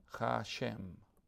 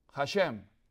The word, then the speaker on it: Hashem: Se dice Hashem, el nombre. Hashem.